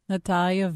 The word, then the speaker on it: Natalia: Natalia Veselnitskaya. – Oh, I didn't say it with the flair that you did for crying out. – Her name's not Natalie. It's Natalia. – Natalia Veselnitskaya.